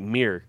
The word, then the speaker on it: mirror: It's like mirror. M-E-E-R. That's how we say it. Mirror. – Mirror. – It's mirror. – Mirror. Yeah. Saying that extra syllable is too much work.